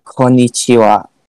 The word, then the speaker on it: konnichiwa: So if you were going to say hello, it would be the word konnichiwa. However, it's not like you can only say that word, right? It's broken down into syllables, right? You have ko, ni, chi, wa, and when you're writing it, you write each syllable independently too.